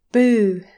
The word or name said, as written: boo!